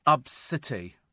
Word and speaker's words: Abcde: Her daughter's name is spelt A-B-C-D-E. OK, but it's pronounced Absity. Absity. It's pronounced Absity. I mean, I don't want to be rude, but it's A-B-C-D-E pronounced Absity.